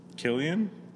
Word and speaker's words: Cillian: Killian? – Whatever his name is. – I can see that. – If you're going to have a fucking name, you know, spell it right.